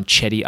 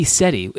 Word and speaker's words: Cedi: Look, if we're talking NBA pronunciation of names, can we just spend a minute on Chetty Osman? Like, that's it. – Why can't it be Seti? Isn't that name spelled C-E-D-I? Isn't that Seti?